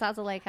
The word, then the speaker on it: azelaic: So these are a multi-fruit and azelaic. I don't know if I'm saying that right. – It's azelaic acid. – That sounds right.